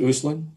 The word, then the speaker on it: Uslan: Did I pronounce his last name right? Is it Uslan? – Yes. – Yes.